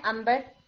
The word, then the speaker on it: अंबर: जी बिल्कुल, अन बर आप लिखते हैं नून के साथ, और आप उसे कैसे बोलते हैं, अंबर, आइन नून बार, और अब उसे कैसे बोलते हैं, अनबर लिख के अंबर बोलते हैं।